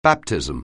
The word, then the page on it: baptism: anglais